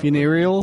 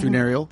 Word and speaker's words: funereal: How do you pronounce that? – Funereal. – Word? – Funereal. – Thank you very much.